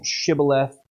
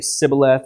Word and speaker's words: Shibboleth: So instead of saying Shibboleth, they would say Sibboleth or something like that.